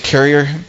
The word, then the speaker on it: Kirche: It sounds like carrier, but it's actually spelled K-I-R-C-H-E, which I would call it Kirch, but that's not how they say it.